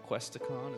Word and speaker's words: Questacon: We got to see Questacon.